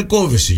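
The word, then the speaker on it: Kövesi: Θα μπορούσαμε να τη λέμε Κόβεση για πιο σωστά, αλλά τη λέμε Κοβέση. Αν και τονίζει στο ο.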